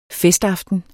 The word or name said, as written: festaften